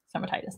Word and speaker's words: stomatitis: Stomatitis. That's how you pronounce that.